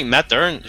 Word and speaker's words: Mathern: La voix que vous avez entendue présentement est la voix de Julien Matern. J'ai dit bien ton nom de famille, j'espère. – Matern, Colin. – Pas loin. C'est le prof. Tu le prononces comme après tous les Québécois. C'est parfait.